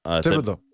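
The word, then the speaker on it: Thibodeaux: Some people, like, for instance, like the defense, I'm drawing a blank on how to pronounce his name, Thibodeau, I believe. The Oregon. – Thibodeau. I think it's pronounced the same as the basketball coach.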